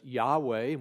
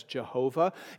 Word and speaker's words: Yahweh: Our English term, Lord, represents the famous covenant name of God, that four-consonant Hebrew name that we pronounce as Yahweh, and we spell it out that way, Yahweh. Sometimes it's found as Jehovah.